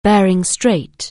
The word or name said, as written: Bering Strait